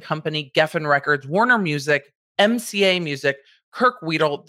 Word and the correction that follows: Weddle: the photographer, Weedle, I guess, is the Pokemon. Weddle.